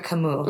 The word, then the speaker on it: Camus: So there is, you know, Albert, I'm going to say his name and you can help me say it the Frenchy way that Albert it was intended. Camus. Albert Camus. Mm-hmm. Yes. Camus, French philosopher, novelist, existentialist, who, one of my favorite quotes of his in a work of his called The Myth of Sisyphus.